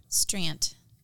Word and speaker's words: Strandt: Strandt.